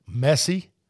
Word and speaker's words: Messi: The Inter-Miami team has now officially inked Lionel Messi.